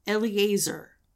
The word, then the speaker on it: Eleazar: And it involved, and I had to look up how to pronounce his name, Eleazar, the priest, and the specific rules of performing this purification ceremony.